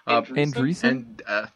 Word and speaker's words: Andreessen: I don't know how to pronounce that guy's last name. – Andreessen? – There you go.